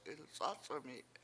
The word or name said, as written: ilc7Ac7ami7